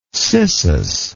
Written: cissus

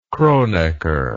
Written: Kronecker

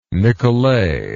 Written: Nicolay

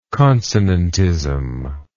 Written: consonantism

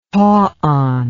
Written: tauon

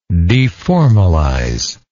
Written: deformalization

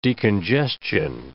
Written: decongestion

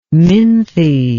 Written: minthe

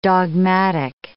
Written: dogmatic

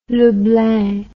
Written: Leblang

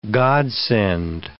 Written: godsend

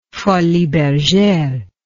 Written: Folies Bergere

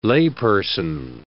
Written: layperson

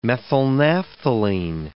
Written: methylnaphthalene

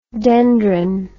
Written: dendron

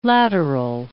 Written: lateral

